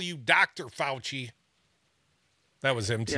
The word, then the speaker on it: Fauci: I think what the Republicans did, the Fucci, how do you say that name? – Fauci.